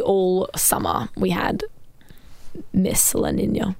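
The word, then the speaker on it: La Niña: And then the rest was kind of, I always say it wrong, but is it La Nina? – La Nina.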